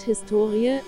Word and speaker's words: Historie: Das Wort Historie, HS2IE, die Betonung liegt auf dem O bezeichnet bis in das 18.